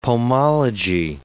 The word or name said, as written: pomology